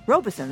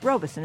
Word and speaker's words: Robeson: Robinson Professor. Robeson, that is, actually. It's Robeson, isn't it? I always mispronounce that.